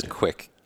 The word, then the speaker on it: Kwik: So my last name is Quick, but the only thing is people have to, it really is my last name. You just have to spell it right. It's K-W-I-K. – Yeah. – K-W-I-K.